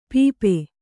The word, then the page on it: ಪಾಪ: Kannada